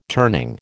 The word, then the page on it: turning: American English